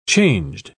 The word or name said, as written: changed